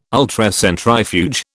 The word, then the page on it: Ultracentrifuge: British English